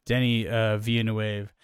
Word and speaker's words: Denis Villeneuve: I say that snarkily to then say that I just heard it I was watching some videos about 2049 afterwards and they pronounced it Denis Villeneuve.